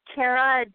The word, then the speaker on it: Kara: Is it Kara? – It's Kara Demonet. – Demonet. That's so much more beautiful than what I was saying.